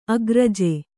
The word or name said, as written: ಅಗ್ರಜೆ